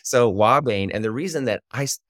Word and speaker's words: ouabain: And the active ingredients are cardiac glycosides, including one called Wabain, which is spelled O-U-A-B-A-I-N.